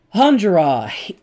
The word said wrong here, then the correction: Enjolras: Enjolras. Fucking French and their silent S's.